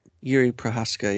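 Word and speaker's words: Jiří Procházka: Jiří Prochazka, yeah.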